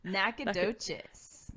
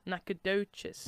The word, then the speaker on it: Nacogdoches: Nacogdoches. – Nacogdoches. I say it like a duchess. Nacogdoches. – You got it. There's a G in there, too.